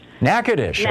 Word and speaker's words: Natchitoches: Natchitoches.